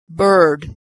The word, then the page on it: bird: American English